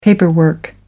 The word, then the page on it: paperwork: American English